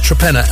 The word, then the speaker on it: Trepenna: I later found out it was, in fact, Trepenna. That's right. Yeah, Trepenna, as in Torpenhow.